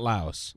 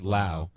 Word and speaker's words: Laos: Is that how you pronounce it? The S is silent? – Yeah. – So it's not Laos. – No. – It's Laos. – But if you're American, I guess that's kind of what we say.